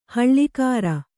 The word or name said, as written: ಹೊಳೆಗರ